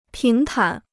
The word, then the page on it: 平坦: píng tǎn